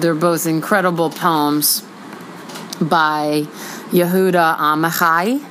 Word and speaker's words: Yehuda Amichai: I said that properly, which made me feel a little bit weird about myself. Yehuda, it looks like Yehuda Amichai, like you're going to go get an Oprah chai tea latte.